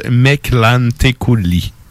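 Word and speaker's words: Mictlantecuhtli: Et si je me fie aux internets, ça se prononce Meclanteculi.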